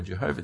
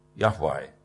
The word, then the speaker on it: Yahweh: It's Y-H-W-H in the Hebrew language, and we're not even completely sure how to pronounce it. It tends to be pronounced Jehovah in a lot of the older versions of the Bible, so if you read it, a King James version, you'll see the word Jehovah there. But we're not sure that that's how it is meant to be pronounced. In fact, scholars in more recent times think it probably should be pronounced Yahweh. Yahweh.